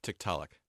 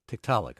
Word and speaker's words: Tiktaalik: First of all, help me with the Inuit pronunciation. – Tiktaalik. – Tiktaalik.